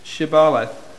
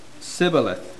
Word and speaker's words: Shibboleth: Then said they unto him, Say now, Shibboleth. And they said, Sibboleth. For they could not frame to pronounce it right.